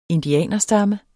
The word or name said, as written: indianerstamme